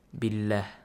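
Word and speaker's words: Billah (بالله): So if there's a kasra sound before the word Allah, the lam in the word Allah is pronounced lightly. – Like example? – Billah.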